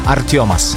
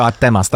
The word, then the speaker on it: Artemas: Artiomas. – Wow, naprawdę. – No, tak się wymawia, wiesz, a ty byś powiedział Artemas, tak? – Tak bym powiedział, no.